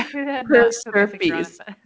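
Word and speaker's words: per stirpes: per-stirpees sounds so fucking disgusting. – I know, doesn't it? – Oh, okay, it rhymes with herpes, that's why. Sorry, I per-stirpees.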